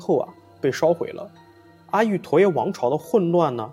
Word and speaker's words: 阿育陀耶: 那再后来呢，阿北找到了一个在加拿大留学的亲友，他班上有很多东南亚人，阿北就跟他讲这个梦，让他帮问问这个叫做阿利图雅的这个说法啊，就这个发音的说法。他问了之后啊，这个就是这个亲友的泰国同学纠正了他的发音，他告诉阿北说应该发音是叫做阿育陀耶啊。